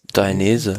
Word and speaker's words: Dainese: Äh, Fabio Jakobsen äh, gewinnt vor Alberto, De, De, De, wie spricht man ihn aus? Dainese? Dainese. Dainese.